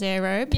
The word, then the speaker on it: Aerobe: I read it as Aerobe. – Yeah, okay, because it's like wardrobe, but air. – Aerobe.